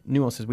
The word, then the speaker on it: nuances: Nuances. We use the French word nuances. – Okay. – Nuances. We pronounce it. It's not quite like the French do, but that's the way we do.